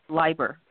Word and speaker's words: Leiber: It's Leiber with a long I.